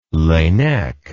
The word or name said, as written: Laennec